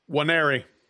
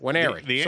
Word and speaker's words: Nwaneri: If that recruit does not commit to an in-state school, so if Williams Nwaneri, how do you say it again? – Nwaneri, that's it, Nwaneri, sorry. – Yeah. – N is silent.